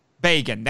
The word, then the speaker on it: Bagan: That's how I say it. I've also heard it pronounced Bagan. – Bagan. – Or yeah, there you go. But I say Bagan. That's what it looks like to me. That's what it looks like to this dirty, dirty American right now. That he's Bagan. Well, rhymes with Fagin, well.